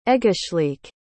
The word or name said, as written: egeslic